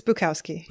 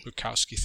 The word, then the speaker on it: Bukowski: I think it's pronounced Bukowski. I'm not sure exactly how his last name is pronounced, but it's Bukowski. – Bukowski, thank you.